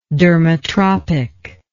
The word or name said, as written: dermotropic